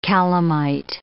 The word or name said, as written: calamite